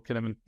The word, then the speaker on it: Cynefin: So that's how I heard him pronounce it. You know, watch videos on YouTube, and I'm sure you can see it in the link below. But yeah, that's how I pronounce it as well, Cynefin.